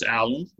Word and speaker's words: Allaun: So uh, Chris Allaun, last name is spelled A L L A U N.